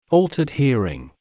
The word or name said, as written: altered hearing